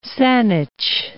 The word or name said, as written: Saanich